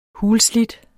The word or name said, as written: hulslidt